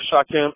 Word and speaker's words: Shakem: So, if you go on Facebook, you know, my name is Shakem, S-H-A-K-E-M, Akhet, A-K-H-E-T.